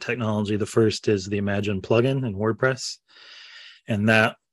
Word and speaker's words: Imaginn: It's URL. It's imaginn.ai. So Imagine AI.